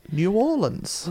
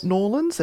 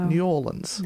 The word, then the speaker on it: New Orleans: Got to say it right. New Orleans. – Well, New Orleans. – Not Norlands. They don't like Norlands. They like New Orleans.